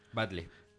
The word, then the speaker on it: Batlle: Batle. – Sí, Pache, Batle. Porque se escribe B-A-T-L-L-E.